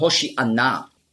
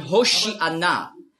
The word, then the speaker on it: Hoshi'anna: Hosianna ist einfach die griechische Aussprache von Hoshi'anna. Hosianna, das ist die griechische Aussprache. Weil die Griechen, die haben im Alphabet kein Sch-Laut. Nur S. Die Hebräer schon. Auf Hebräisch sagen Hoshi'anna. Aber Griechen können das nicht.